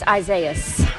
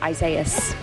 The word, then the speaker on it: Isaias: How do you say it? Is that Isaias? – It's Isaias. – Isaias. – Like Isaiah with an S. Isaias.